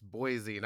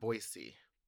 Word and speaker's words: Boise: My response would be, I just want to make sure we're focusing that for me, it's about an S rather than a Z, not the vowel sound. – Yeah, and he was saying it's Boise, not Boise.